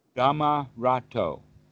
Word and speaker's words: Dhammarato: The Dhammarato.